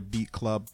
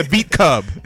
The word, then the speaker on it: Beat Club: Yeah, welcome back to the Beat Club Podcast. I said it right this time. It's not the Beat Cub.